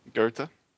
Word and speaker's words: Goethe: And Goethe, am I saying that right? – Goethe. – Oh, wow. – That's a German name, don't worry about that one.